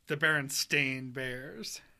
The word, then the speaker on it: Berenstain: The Berenstain Bears.